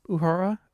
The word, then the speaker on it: Uhura: Uhura.